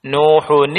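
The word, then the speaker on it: Nuhun (نوحٌ): Wanada Nuhun ibnahu. Ini bacanya gitu ya. Saya pernah dengar ada membaca wanada Nuh ibnahu. Itu salah. Yang benar wanada Nuhun ibnahu.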